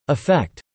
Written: effect

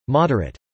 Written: moderate

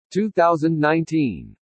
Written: 2019

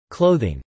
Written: clothing

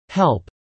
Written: help

